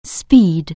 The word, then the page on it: speed: anglais